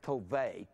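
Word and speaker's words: tove (טוב): And the word good is T-O-W-B, T-O-W-B. It's actually pronounced tove. The W, that's a valve, and it can be pronounced V. It actually sounds tove in the Hebrew, and it means beneficial.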